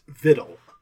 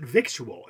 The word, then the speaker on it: victual: It's, as we said, it's spelled with a C, victual it's spelled as, but it's pronounced, it has been and always has been pronounced vittle. Until, apparently, modern times when people see the word and they pronounce it victual.